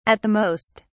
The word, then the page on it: at the most: British English